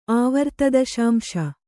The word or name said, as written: ಆವರ್ತದಶಾಂಶ